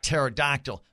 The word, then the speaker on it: pterodactyl: He said, I should have done a question about pterodactyl, but that starts with a P, and that would have been all sorts of controversy. It's a silent P.